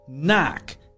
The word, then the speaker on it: knack: It's knack, K N A C K. The first K is silent.